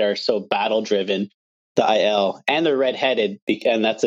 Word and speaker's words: Aiel: Like he's talking about the Aiel. Um, I call them ales my entire life, but apparently that's how you say it.